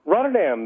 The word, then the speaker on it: Rotterdam: You are listening to us online in, is a dumb question, is it pronounced Rotterdam? Rotterdam? – Rotterdam, the Netherlands, yes.